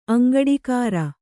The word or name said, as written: ಅಂಗಡಿಗೇರಿ